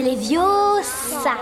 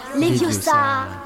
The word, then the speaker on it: Leviosa: Et puis d'ailleurs, tu ne sais même pas le prononcer. C'est Leviosa. Et pas Leviosa.